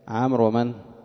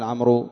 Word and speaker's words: عمرو: التفرقة بين عمر ومن؟ وعمر. عمر وعمر، عمر بعد الراء تكتب واوا ولا تنطقها، فلا يقال عمرو إنما يقال عمر، عمر عند الرفع، عمرا عند النصب، عمر عند الجر.